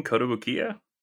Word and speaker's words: Kotobukiya: Is that really how you pronounce that name, Kotobukiya? – Yeah.